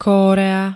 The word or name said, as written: korea